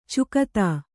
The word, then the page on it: ಚುಕ್ತಾ: Kannada